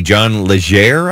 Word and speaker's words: Legere: I'm not sure how you say his name. It's L-E-G-E-R-E. John Legere, I think.